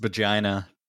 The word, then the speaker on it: Bajirao: But soon the thug faces the full force of cop Bajiro Singham. B-A-J-I-R-A-O. Bajiro? – It's pronounced Bajina.